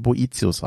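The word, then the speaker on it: Boethius: Äh, ich habe sehr oft Bötius gesagt. Und auch da ist es wohl so, dass das richtig ausgesprochen, bo, richtig ausgesprochen Boetius heißt.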